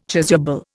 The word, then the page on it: chasuble: Indian